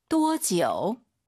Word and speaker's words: 多久: 多久? – 또 지우 아니고 아이유 사이에 오 넣어서 또 지우.